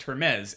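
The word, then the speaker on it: Hermès: I just know that they're pronounced Hermes.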